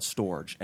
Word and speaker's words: Storj: So, you know, in reading articles about Storj, and for everyone listening, that is spelled S-T-O-R-J, like storage, and we'll get into, you know, why that makes sense.